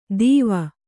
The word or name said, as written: ದೀವ